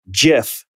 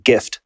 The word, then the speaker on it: GIF: Most people think 77.8% say it's with a hard G, like gift.